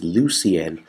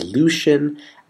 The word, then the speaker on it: Lucien: Like revolution, revolutionary, and my name, which is Lucien, which people often mispronounce as Lucian,